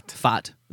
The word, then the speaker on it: PFADT: we've got another company, FAT. – FAT. – That's, I believe that's the correct way to say it. – P-F-A-D-T. – P-F-A-D-T.